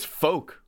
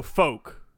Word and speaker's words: Fouke: It's folk. I just Google it. It says folk? – F-O-U-K-E Arkansas rhymes with poke. – Okay. So folk.